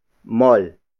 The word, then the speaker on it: mall: Mall is spelled as M-A-L-L. It means mall, the location where we are going without you. As you may have noticed, Filipinos pronounce mall with a Tagalog O sound, as in mall, compared to how English speakers pronounce it, like mall. And then the next word is mamaya. Mamaya. Mamaya. Mamaya is spelled as M-A-M-A-Y-A.